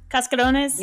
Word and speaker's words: cascarones: Cascarones? – Yeah.